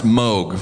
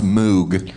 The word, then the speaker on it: Moog: It is pronounced Moog. For all you fuckers, it's not pronounced Moog.